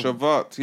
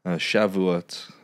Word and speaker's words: Shavuot: Shavuot. Yeah, yeah, yeah. – I can play it. – Shavuot, apparently.